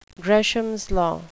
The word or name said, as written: gresham's law